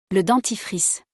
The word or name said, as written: Le dentifrice